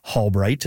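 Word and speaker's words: Halbrite: That's so interesting, and I can confirm it's Hallbright. It's spelled Halbright.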